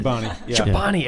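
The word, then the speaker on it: Chobani: I think Shabini won that one. – Shabani, every time.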